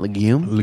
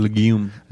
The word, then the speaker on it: legume: and you're not only eating a healthy legume. Is that how you pronounce that? Legume? – Legume.